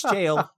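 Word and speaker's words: gaol: That's jail.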